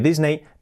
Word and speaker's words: Disney: Pues nosotros decimos Disney porque se escribe Disney, pero que he dicho antes, que las palabras acabadas en E-Y, pues es una I. Por lo tanto, sería Disney, Disney, Disney.